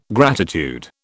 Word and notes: gratitude: British English

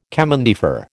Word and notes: chemin de fer: American English

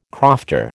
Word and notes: crofter: American English

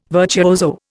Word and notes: virtuoso: Indian

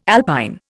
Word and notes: alpine: Indian

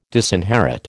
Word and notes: disinherit: American English

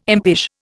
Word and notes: impish: Indian

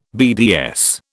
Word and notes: BDS: British English

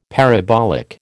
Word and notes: parabolic: American English

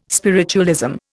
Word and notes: spiritualism: Indian

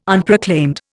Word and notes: Unproclaimed: Indian

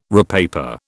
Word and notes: repaper: British English

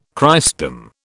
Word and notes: Christendom: British English

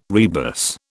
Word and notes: rebus: British English

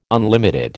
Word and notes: unlimited: American English

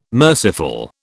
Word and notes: merciful: British English

